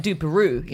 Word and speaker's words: DUPAROOH: Dupero, D-U-P-A-R-O-O-H. – I think it's more like Dupero, you know. – Oh, Dupero. – Yeah. Like Dupero, like Winnie the Pooh. Dupero.